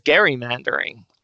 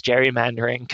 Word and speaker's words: gerrymandering: Perhaps the most surprising thing was that I learned that it was originally called gerrymandering, and it was only over time that the kind of more natural pronunciation of it as gerrymandering kind of caught on and eventually entirely replaced the original way that the word had been pronounced.